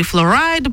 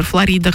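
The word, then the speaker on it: Flo Rida: После него уже и Флорайда приезжал, хотя в России его называют рэпер Флорида, хотя он Флорайда.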